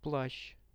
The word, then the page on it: plashtsh: Russian